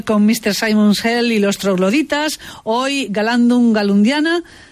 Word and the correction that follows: Galandum Galundaina: o Galandum Galundaina, mejor dicho.